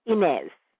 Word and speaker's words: Inez: No, and in Jamaica, that spelling is pronounced Inez.